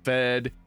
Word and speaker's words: Fede: We did have the little question, I guess, last episode of if it was Fede or Fed. It's short for Federico, though there is an E on the end of it. You're not pronouncing it. So it's just the short form of Federico, Fed.